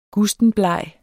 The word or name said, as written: gustenbleg